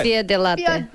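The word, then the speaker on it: fior di latte: Fior de latte.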